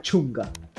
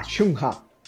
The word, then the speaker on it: Chungha: Ella es una artista solista, es muy popular, se llama Chunga, okay. Se escribe Chung, H-A, Chungha, okay, Chungha.